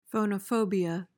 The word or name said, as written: phonophobia